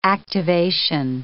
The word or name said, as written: activation